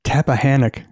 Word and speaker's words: Tappahannock: I'm going to try to say this correctly. Tappahannock, Virginia. – You said it right as far as I'm concerned.